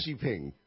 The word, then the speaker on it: Xi Jinping: like a poodle, Xi Xi. It's yeah. Xi Jinping. – Xi Jinping. Yeah. I have to remember that. – Here's Xi Jinping.